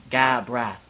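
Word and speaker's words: Gabranth: He actually pronounced it for me. Gabrath. – That's actually a good game as well, Final Fantasy XII. – Gabrath. – It's Gabrath. – Gabrath? – It's exactly the way it's spelled. Like, Gabrath.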